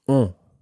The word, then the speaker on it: ont: And that's spelled O-N-T. Ont. – Ont. – Ils ont. – Ils ont. – And make sure that you sort of make that an on sound as opposed to an en sound.